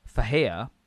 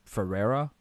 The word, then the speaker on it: Ferreira: So I'm gonna try and say his name the Brazilian way. So it's Carlos Faheia, which is, if anyone else read it, I guess it's like Ferreira, right?